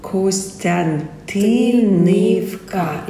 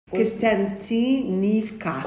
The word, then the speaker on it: Kostiantynivka: We believe that it is very important for the whole world, doesn't matter where we're from, to respect the Ukrainian pronunciation in Ukrainian language. – Kostyantynivka. – Kostyantynivka. – Yeah, so it's we usually transliterate it like Y, yeah? – Kostyantynivka.